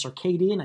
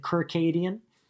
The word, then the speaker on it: circadian: I believe the first time I asked that question at a live event, I mispronounced circadian. I think I said Kirkadian.